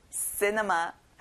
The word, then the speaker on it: cinema: 영화관, 극장, cinema. 친구들 앞에 C가 있는데 크 소리가 안 나요. 그리고 C에 강세를 넣어볼까요? Cinema. Very good.